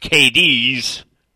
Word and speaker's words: Cadiz: It's spelled C-A-D-I-Z, but it's pronounced KD's, Kentucky.